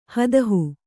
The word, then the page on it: ಹದಹು: hadahu